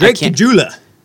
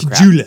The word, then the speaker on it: Caggiula: Caggiula. – Yeah, that Caggiula, one. – Sorry. – I can't pronounce his name for Caggiula.